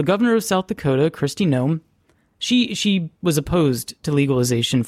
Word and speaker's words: Noem: Unfortunately, their governor, Kristi Noem, I'm not sure I'm saying that right. It's N-O-E-M,